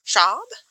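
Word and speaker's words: Schwab: Then A Darker Shade of Magic by V.E. Schaub, I believe is how you say the last name.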